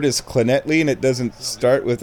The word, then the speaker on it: Llanelli: Clonetley is spelled L-L-A... N-E-L-L-I. That's right. Clonetley. Starts with two L's, ends with two L's and an I. Clonetley. L-L-A-N-E-L-L-I. Clonetley. So there's four L's. Four L's in this word, in the name of this town. There are four L's, and there are three different pronunciations of the letter.